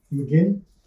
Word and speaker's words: McGinn: I like to think of do you gamble, Mr. McGuinn? – McGinn, not McGuinn. – McGinn.